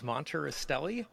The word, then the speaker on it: Monterastelli: Um, it's, it's, it's, perfectly phonetic. Just say all the letters. Monterastelli.